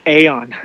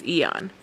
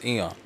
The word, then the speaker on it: Aeon: And then Eon, did you know that that's how you say it? – Eon? – Yeah, I was asking Will. – Oh. – I've been saying Aeon. – Same. I was told Aeon until I think finally it might have been Emily who corrected me. And it's Eon. – It's Eon. – Yeah, Eon. Even though there's an egg, maybe the A is silent.